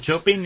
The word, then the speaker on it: Chopin: Chopin is, you know, as you write, but the pronunciation is Chopin. – I know. – Chopin. – Chopping, like in the kitchen.